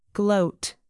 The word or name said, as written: gloat